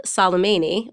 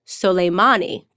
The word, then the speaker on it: Soleimani: I think a lot of Americans are, given the recent news that we killed Soleimani. I'm saying his name wrong. I've just learned, actually, it's Soleimani, because I am here with Lisa Daftari, who is an Iranian guest who specializes in a lot of foreign policy.